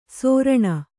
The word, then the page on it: ಸೋರಣ: sōraṇa